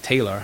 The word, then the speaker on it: Taylar: Taylor, T-A-Y-L-A-R, like Taylor. First time I heard that. – Taylor? – Well, I guess it's Taylor, but it's spelled T-A-Y-L-A-R, Taylor.